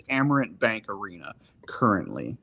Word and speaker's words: Amerant: Amerant, yeah.